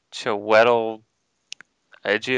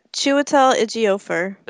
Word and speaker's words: Chiwetel Ejiofor: Chiwetel Ejiofor. – Oh, yeah, Chiwetel Ejiofor.